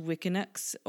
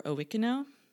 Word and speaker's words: Wuikinuxv: First Nations people, the Wikino, it's spelled W-U-I-K-I-N-U-X-V, which is not a combination of consonants that I am equipped to deal with. So I looked it up, and it's pronounced Wiccanux or Owiccano.